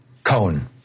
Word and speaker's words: Cohn: Then finally McCarthy and Ray, you said Ray Khan or Ray Cohen? – Cohen. One syllable. – Cohen. Okay. – Cohen. – C-O-H-N.